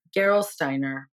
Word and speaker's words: Gerolsteiner: Gerolsteiner.